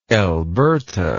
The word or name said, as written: elberta